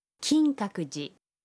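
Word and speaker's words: کینکاکوجی (金閣寺): کینکاکوجی.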